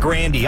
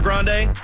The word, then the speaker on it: Grande: Do you know we've been saying her last name wrong this whole time? You actually pronounce it Grandy. I heard her say that in an interview one time.